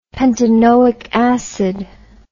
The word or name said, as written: pentanoic acid